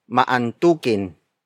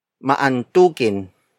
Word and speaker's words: maantukin: Antok is spelled as A-N-T-O-K. It means to yawn. In adjective form, it becomes maantukin. Maantukin. Maantukin. Maantukin is spelled as M-A-A-N-T-U-K-I-N.